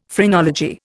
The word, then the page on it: phrenology: Indian